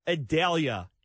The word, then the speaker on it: Idalia: And Renee, we are watching this Hurricane Idalia. And I think that's correct. Yesterday on the news, they actually spent a good amount of time telling us how you pronounce it, but I forgot already. – I think you have it right. And that pronunciation came from the National Weather Service.